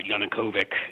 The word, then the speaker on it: Yanukovych: Yanukovych, if I'm pronouncing the name right.